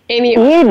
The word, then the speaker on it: Amy Oestrecher: Did I say that right, Amy? – Amy Oestrecher. You did.